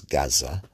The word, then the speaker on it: Gaza: Many of these people, especially in Gaza, how we pronounce it in the West as Gaza, they have lost, they are descendants of refugees.